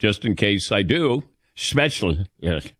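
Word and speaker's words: Spätzle: It's not special. It's not Spetzel. It's Spetzla.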